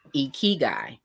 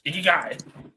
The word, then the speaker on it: Ikigai: So the word is actually Japanese, and it's pronounced Ikigai. – Ikigai.